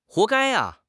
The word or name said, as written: 活该